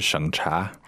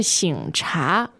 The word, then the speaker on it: 省: 那这里这个省是一个多音字。它也是省份的这个省。 – 对，一开始我以为是省察。 – 哎，其实应该是省察。